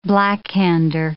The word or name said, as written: blackhander